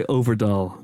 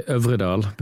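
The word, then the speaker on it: Øvredal: Andre Ovredal, which is your actual pronunciation. You just told me that's because I was getting it all wrong. – Yeah, no, it's good. I mean, in Norway, we would say Ovredal, but that's...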